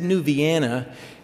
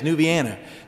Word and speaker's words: New Vienna: We said New Vienna, and it's spelled V-I-E-N-N-A, and it didn't hit me until a couple of years ago that that's Vienna, as in Austria, New Vienna, but in Ohio it's just New Vienna.